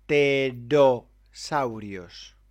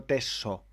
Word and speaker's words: pterosaurios: Está hablando de los pterosaurios. Pterosaurios. Con R. No tesosaurios.